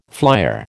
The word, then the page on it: flyer: American English